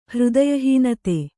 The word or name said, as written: ಹೃದಯಹೀನತೆ